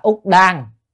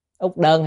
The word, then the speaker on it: Úc Đơn: Chữ đầu tiên Úc Đơn, cái chữ này còn có thể đọc là Úc Đan, Úc Đơn hay Úc Đan được, đọc âm đơn đan vậy hết.